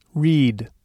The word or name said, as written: read